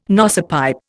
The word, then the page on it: nosepipe: Indian